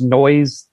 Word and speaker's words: Noizze: Same thing with Noizzy. That's spelled N-O-I-Z-Z-E. I think it's actually pronounced Noise, as they say, but I always call it Noizzy. Not to confuse it with the actual Noizzy, which is N-O-I-S-E-Y. But yes. N-O-I-Z-Z-E, guys, is how you find it.